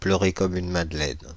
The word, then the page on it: pleurer comme une Madeleine: France (Île-de-France); [plœ.ʁe kɔ,m‿yn ma.dlɛn]